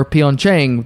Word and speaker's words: Pyeongchang: Pronunciation of Pyeongchang. Many believe it's pronounced Pyeongchang or Pyeongchang. The correct pronunciation is Pyeongchang.